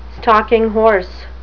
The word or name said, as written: stalking-horse